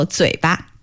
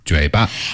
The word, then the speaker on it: 嘴巴: And 巴 there is the light tone. So 嘴巴。 – 嘴巴。